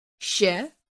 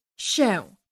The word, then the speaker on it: się: If the nasal vowel l appears at the end of a word, you can pronounce it either as a nasal vowel l or as a front oral vowel e. For example, the word się can be pronounced as się or się. Both pronunciations are correct.